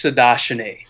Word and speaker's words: Sahadachny: It's Sahadachny.